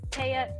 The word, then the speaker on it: Te’a: It's Te’a. You actually said it right. It was Te’a Elani.